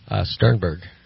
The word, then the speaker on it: Sternberg: Sternberg. – Sternberg. – All right. How do you spell it? – S-T-E-R-N-B-E-R-G.